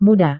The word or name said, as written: muda